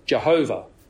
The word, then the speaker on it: Jehovah: And that word Lord is the capitalization that is standard in our English translations, capital L-O-R-D, which tells us that behind it comes the holy covenant name of God. Usually these days it's pronounced as Yahweh, though in earlier times it was often pronounced as Jehovah.